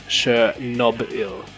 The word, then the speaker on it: Chernobyl: And um, as far as I could tell, it's Chernobyl. Like B belongs to the second syllable, not the third syllable, as a lot of people pronounce it.